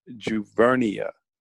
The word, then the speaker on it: Juvernia: No problem, because you're not the first and you won't be the last. But it's pronounced Juvernia.